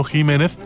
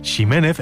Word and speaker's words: Ximénez: Así, por ejemplo, la variedad de una uva conocida como Pedro Jiménez se pronuncia así, aunque ese Jiménez se siga escribiendo con X, si bien también se escucha mucho decir Ximénez e incluso Ximénez cuando se pierde ya la referencia de su pronunciación original.